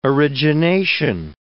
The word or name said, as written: origination